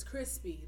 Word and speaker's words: Chrispy: My name is Crispy. That is spelled C-H-R-I-S-P-Y.